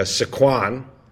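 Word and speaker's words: Sycuan: The other thing I want to ask is about that the part of the ownership group is working with I hope I'm saying it correctly. Sikwan National Tribe. – Mm-hmm. Yeah, Sikwan. – Sikwan.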